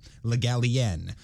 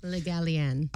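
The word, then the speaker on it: Le Gallienne: Asked how to say his name, he told the Literary Digest the stress was on the last syllable, Le Gallien. As a rule, I hear it pronounced as if it were spelled Gallien, which, of course, is wrong.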